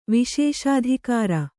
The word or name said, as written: ವಿಶೇಷಾಧಿಕಾರ